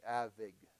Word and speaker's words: Avignon: How do you say that? Avignon. Can anybody say that for me? Avignon. Okay, I'll just say it like that. Very good.